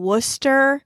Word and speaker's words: Worcester: All she could find at first was a sub position for a couple of months in Connecticut, followed by the irritating to pronounce Leicester Academy in Worcester. I assure you, none of that looks like it's spelled.